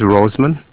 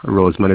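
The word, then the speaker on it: Rosemond: My name is Roseman and this is spelt Romeo Oscar Sierra Echo Mike Oscar November Delta. Roseman is my name.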